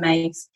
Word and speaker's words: Myss: her surname is spelled m-y-s-s for anyone who doesn't know her but it's pronounced caroline mace